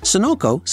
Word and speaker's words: Sunoco: Brand twins can exist phonetically too. For example, there is Sunoco and Sonoco. Sunoco, spelled with a U, is in the petroleum business, and Sonoco, spelled with an O, is in the consumer and industrial packaging business. Then there is also Sysco and Cisco.